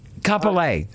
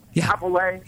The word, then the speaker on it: Kapolei: Do you pronounce it Kapolei? – Kapolei. – Kapolei.